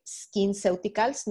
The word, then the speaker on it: SkinCeuticals: Escuché también un producto que mencionabas que se llama Blemish and Age Defense de SkinCeuticals, no sé si lo pronuncié bien.